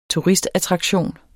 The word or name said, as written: turistattraktion